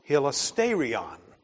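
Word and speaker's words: hilasterion (ἱλαστήριον): It's pronounced hilasterion.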